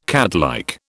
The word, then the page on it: catlike: British English